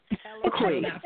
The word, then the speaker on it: Eloquii: Eloquii.